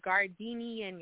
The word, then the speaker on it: Gardenian: It's pronounced Gardenian, y'all. I'm going to spell it for y'all again. G-A-R-D-E-N-I-A-N Gold. Gardenian Gold.